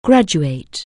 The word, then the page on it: graduate: anglais